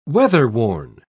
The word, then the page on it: weatherworn: British English